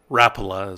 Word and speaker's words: Rapala: Um, and a guy caught a 29 inch brown trout on a Rapala or Rapala is, I guess, how they say it on TV. We here in the Uinta Basin call it Rapalas, but I guess back east there's Rapala, brought to you by Rapala.